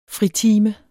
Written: fritime

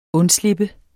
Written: undslippe

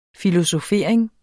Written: filosofering

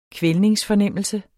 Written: kvælningsfornemmelse

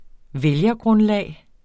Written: vælgergrundlag

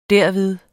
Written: derved

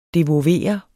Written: devovere

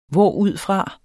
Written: hvorudfra

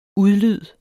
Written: udlyd

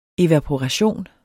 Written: evaporation

